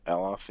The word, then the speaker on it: Aloth: So this character, the wizard, Aloth, is that how you pronounce it? Aloth? – That's how, Aloth, yeah.